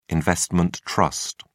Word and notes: investment trust: British English

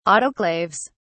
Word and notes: autoclaves: British English

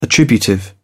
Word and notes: attributive: British English

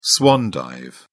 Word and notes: swan dive: British English

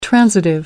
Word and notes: transitive: American English

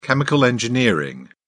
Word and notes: chemical engineering: British English

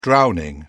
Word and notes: drowning: British English

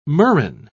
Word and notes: murrain: British English

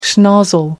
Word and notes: schnozzle: American English

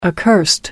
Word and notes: accursed: American English

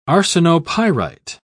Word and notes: arsenopyrite: British English

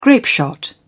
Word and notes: grapeshot: British English

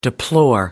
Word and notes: deplore: American English

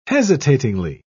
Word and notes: hesitatingly: British English

